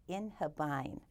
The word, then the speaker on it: inhibine: It's called, I, I believe it's pronounced in inhibine. It's I N H I B I N E.